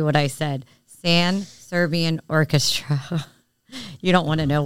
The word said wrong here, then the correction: Trans-Siberian Orchestra: The Sibian Orchestra? No, Trans-Siberian. You've never heard of Siberia, Russia? Or the Trans-Siberian Orchestra, for that matter. It's the Trans-Siberian Orchestra Tribute Band 1224.